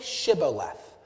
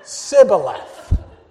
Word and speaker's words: Shibboleth: And so anytime an Ephraimite would try to cross the river, they would say to them, Say Shibboleth. And the people from Ephraim couldn't pronounce it correctly. It'd be like asking people from Northeast Philly to say water. they would say Sibboleth.